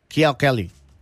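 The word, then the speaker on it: Kiao Kelly (巧克力): Y en China, en chino, me dice Katia Sanchun, se pronuncia Kiao Kelly.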